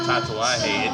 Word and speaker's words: Tatuaje: Man, I know I got to give you all the love on that Tatuaje. It took me just probably a month and a half just to learn how to say it right. – You said it right.